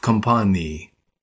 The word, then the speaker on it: компании: Notice how the word f changes the end of компания to a long e sound. В компании.